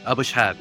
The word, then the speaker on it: Abushhab: Abushhab, yeah.